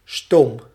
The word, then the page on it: steam: American English; /stiːm/